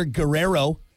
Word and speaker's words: Guerrero: If you happen to catch the Major League Baseball Home Run Derby over the weekend, you probably saw Toronto Blue Jay slugger Vladimir Guerrero, you got to roll your R's, Jr., murdering baseballs.